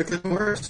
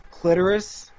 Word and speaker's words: clitoris: The clitoris. – It's pronounced clitoris. Hello? We do not stress the syllables.